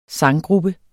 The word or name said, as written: Sanggrupper